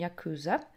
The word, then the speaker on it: Yakuza: Ja, der Begriff Yakuza, ähm, ausgesprochen Yakuza, ist in dem Sinne eigentlich nicht mal der Begriff, wie äh, die Yakuza zu sich selbst sagen würden.